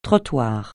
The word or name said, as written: trottoir